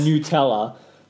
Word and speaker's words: Nutella: So apparently Tom's correct in the pronunciation. – What? – It's Nutella.